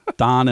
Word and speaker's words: Daan: But it's spelled D-A-A-N and R-A-A-N. Don and Ron.